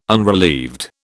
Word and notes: unrelieved: British English

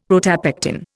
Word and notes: protopectin: Indian

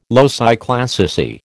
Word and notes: loci classici: American English

